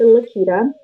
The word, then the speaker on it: Laketta: So and the channel name is Confidence on Stilettos with Laketa. My name is spelled L-A-K-E-T-T-A.